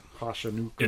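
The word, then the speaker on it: Hachimura: And we got our new player, Muri Hachinuka. I don't know how to say his last name. Sorry, buddy. – Yep, nailed it. – Mm-hmm, mm-hmm. – Welcome to the Lakers. – Yep. – Hachinuka.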